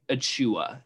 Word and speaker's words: Achiuwa: I think it's Achiuwa is how it's pronounced.